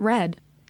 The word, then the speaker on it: read: How do you pronounce read receipts? – I pronounce it red.